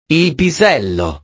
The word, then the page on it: il pisello: italiano